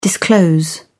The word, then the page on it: disclose: British English